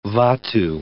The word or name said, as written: vatu